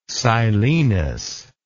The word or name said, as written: silenos